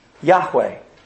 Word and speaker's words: Yahweh: Well, in Hebrew, it's, we think, pronounced this way, just one word, Yahweh.